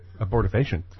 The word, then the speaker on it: abortifacient: could use your abortifacient money to buy porn and then what? Then where would they be morally? – Right. – Well, exactly. – That's a great abortifacient.